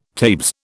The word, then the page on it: tabes: British English